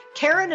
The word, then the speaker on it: Karen: Karen is spelled the normal way, no goofy Y's or Q's, K-A-R-E-N and Kataline is spelled K-A-T-A-L-I-N-E.